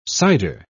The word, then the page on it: cyder: British English